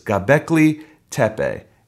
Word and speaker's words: Göbekli Tepe: Göbekli, that's what it is. Göbekli Tepe. And it's in Turkey, and it's one of the oldest archaeological sites, kind of a city and a town, village type thing.